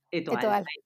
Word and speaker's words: Étoile: Étoile,